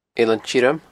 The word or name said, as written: Eilean Tioram